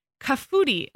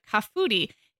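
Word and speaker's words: kahfooty: She said, in response to Chelsea, she said, we say kafooty, and I'm going to spell that. It's K-A-H. – Gosh, so genius. – F-O-O-T-Y. So like kafooty.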